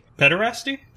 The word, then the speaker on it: pederasty: Uh, and then, do you want to explain what I'm probably getting the pronunciation of the, of the word wrong, but pedestry? – Pederasty? – Pederasty. That's the word. I can't ever say it properly.